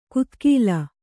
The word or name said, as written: ಗುದಿಗಾಲು